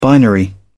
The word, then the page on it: binary: British English